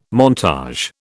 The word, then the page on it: montage: British English